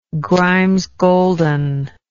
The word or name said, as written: Grimes Golden